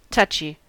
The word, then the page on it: touchy: American English